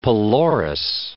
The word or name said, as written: pelorus